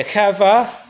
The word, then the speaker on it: qavah: It's chava or chava. Q-A-V-A-H. Yeah, chava.